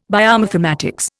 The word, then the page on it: biomathematics: Indian